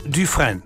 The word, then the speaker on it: Dufresne: Und der schreibt sich folgendermaßen. D-U-F-R-E-S-N-E. Etwa ausgesprochen Dufresne. Dufresne.